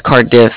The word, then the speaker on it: Cardiff: Bank of Card Debt? – No, it's Cardiff. It's spelled C-A-R-D-I-F-F, like different.